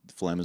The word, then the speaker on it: phlegm: And I was like, F-L-E-M. – Well, there's a silent P and a silent G. Yeah. You don't get two silent letters in a word very often. Yeah. But phlegm is one of them.